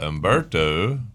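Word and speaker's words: Humberto: There may end up being, but Umberto, which starts with an H, is silent.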